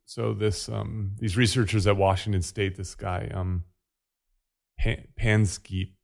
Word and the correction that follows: Panksepp: is that how it's pronounced? Panksepp.